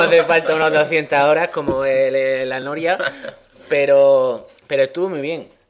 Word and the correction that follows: L.A. Noire: L.A. Noir.